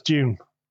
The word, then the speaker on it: Dune: Is it Remember? Dune or is it Dune? – Dune. No, it's Dune. Dune. Dune. I just say it like June, so I try and overpronounce it.